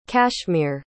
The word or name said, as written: kashmir